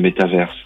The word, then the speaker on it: métaverse: Le mot d'origine, il est anglais. Oui. Il vient de méta et universe. Donc méta, universe devient métaverse.